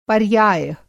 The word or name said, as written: पर्याय